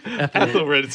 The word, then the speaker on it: Ethelred: Ethelred, sorry, I said his name wrong.